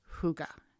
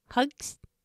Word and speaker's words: hygge: They call it hygge, and it's spelled H-Y-G-G-E. And Sarah says it hugs.